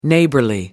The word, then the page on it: neighborly: British English